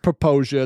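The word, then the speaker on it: Popo Agie: It's one of the most mispronounced rivers. You would just annihilate the name of this if you just looked Popo at the spelling Aggie. Because you really bet. Yeah, that's right. Because it's spelled P-O-P-O. And then space, and then A-G-I-E. So it's pronounced Popoja. It's a Native American thing.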